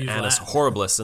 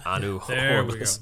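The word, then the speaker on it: annus horribilis: Then she said anus horribilis, and they're like, no, you got to say it correctly. And then it became anu horribilis.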